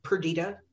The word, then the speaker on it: Perdita: Usually, people say Perdita, and it's fine, but I'm fine with either pronunciation because they're actually right.